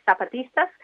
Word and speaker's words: Zapatistas: So, yeah, your pronunciation of Zapatistas is spot on. Obviously, the Z in Spanish is just like an S, Zapatistas.